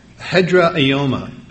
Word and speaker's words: hedraioma: The word ground there is translated from the Greek word hedraioma. Hedraioma, it's spelled H-E-D-R-A-I-O-M-A. And it's referring to a base or a stay or a foundation that makes things stable, underlying the pillars.